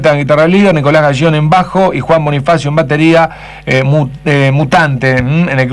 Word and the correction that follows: Bonifacino: En realidad, Bonifacino. – Ah, Bonifacino, porque me está faltando una sílaba, hermano, acá. – Una N, se pierde bastante seguido.